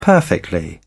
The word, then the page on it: perfectly: British English